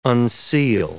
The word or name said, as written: unseal